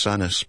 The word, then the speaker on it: Sandness: The road signpost says Sandness, locally pronounced Sanis, but it's Melby.